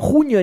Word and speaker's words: Jean: La revista se llama así por el escritor francés Jean Genet, creo que lo estoy pronunciando bien, ponele, cuya pronunciación en español suena como junio en inglés.